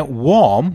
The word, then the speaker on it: warm: Now, warm, which means quite hot, you don't say, "Oh, it's worm today." No, it's warm. Or. In warm, the A-R is the same pronunciation as O-R, the word or. So, warm.